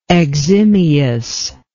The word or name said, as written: eximiously